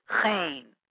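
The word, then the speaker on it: chrain: Today, it usually comprises of small fish balls eaten with horseradish, pronounced chayn, to rhyme with insane, which you would have to be to inflict it on your innards.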